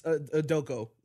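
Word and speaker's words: Udoka: Odoku? – Yeah, yes, Odoku, the first time you pronounced it.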